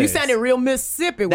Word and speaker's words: Mississippi: Every now and then, y'all, I get like very Mississippi. – Mm-hmm. Mississippi. – Because it's only two syllables to people from there. Mississippi.